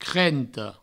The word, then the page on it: crenta: crento